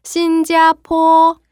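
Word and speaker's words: 신자포 (新加坡): 자, 여기 포는요. P 발음이기 때문에 다시 해 볼게요. 포 발음이에요. 신자포.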